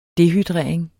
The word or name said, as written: dehydrering